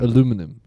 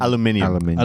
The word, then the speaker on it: aluminium: Aluminum. How do you say aluminum? – Aluminium.